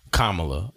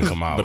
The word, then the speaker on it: Kamala: Kamala. – Okay. – Kamala Harris. – Some people say Kamala. – Yeah, some people say Kamala.